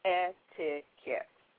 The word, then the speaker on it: etiquette: Now, since we said that the word etiquette comes from a French word meaning ticket, saying the word slowly, you will actually hear yourself say ticket, so etiquette. So, therefore, that's why the Q actually adopts the K sound, so no longer shall the word etiquette be mispronounced and called etiquette, okay?